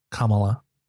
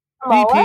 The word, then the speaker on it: Kamala: I keep saying it wrong because of Kamala the Giant from WWE. That was Kamala. It's wrong. It's Kamala.